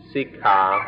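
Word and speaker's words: sikkhā: This word siksa in Thai comes from an old Indian word, which in Sanskrit is pronounced siksha, or in the Pali language, sikha.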